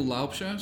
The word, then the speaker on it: Laubscher: It is Michael Laubscher. So Michael.Laubscher. My surname is spelled L-A-U. B-S-C-H-E-R.